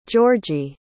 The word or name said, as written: georgie